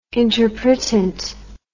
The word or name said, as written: interpretant